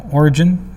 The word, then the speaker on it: Origen: So Origen, am I saying that right? Yep.